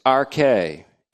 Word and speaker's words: arche: The word for beginning here is arche, A-R-C-H-E, and Arche is how it's pronounced.